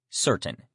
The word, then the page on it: certain: male